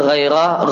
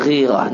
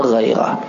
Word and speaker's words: ghairah: Bacanya ghairah, ra-nya di fathah, bukan ra-nya di kasrah, bukan ghairah, namun ghairah.